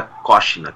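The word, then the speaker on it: Koscina: Então, o pessoal até no Brasil chamava de Kocina, mas a pronúncia parece correta, do sobrenome seria Silvia Kocina, que foi uma sex symbol nos anos 60 na Itália.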